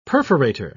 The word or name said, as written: perforator